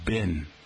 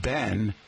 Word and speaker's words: been: How about B-E-E-N? 65% of Americans pronounce the word as if it were spelled bin. If you pronounce it like the name Ben, then guess what, folks?